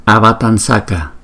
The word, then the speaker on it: Avatamsaka: Trong Kinh Hoa Nghiêm Avatamsaka Sutra, mình đọc là Avatamsaka, vì chữ S thuộc vùng dental nên đổi chữ Anuswara M có dấu chấm thành chữ N cũng thuộc vùng dental.